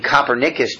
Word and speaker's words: Copernicus: Now here you see the Bible recognizes it wasn't, you know, I mean, Copernicus discovered this, or whatever, however you pronounce his name.